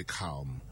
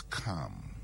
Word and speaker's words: calm: So, in and another one is calm. People say calm. The word is calm. You are calm, not calm. Same with palm. Palm. It's not palm.